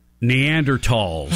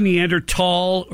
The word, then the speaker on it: Neanderthal: Neanderthals. Did he say Neanderthal or did he say Neanderthal? – I doubt he said it right. I doubt it. – You're supposed to say tall.